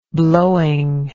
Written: blowing

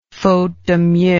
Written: faute de mieux

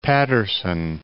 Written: Paterson

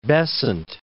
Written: Besant

